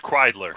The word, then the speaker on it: Kreidler: Is that Creedler or Kreidler? How do you pronounce it? – It's Kreidler.